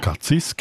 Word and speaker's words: Cazis: Sie ist ursprünglich von Chur, ist aber im Moment in Cazis. Habe ich das richtig gesagt, Frau Karrer? Jawohl, genau. – Cazis, genau.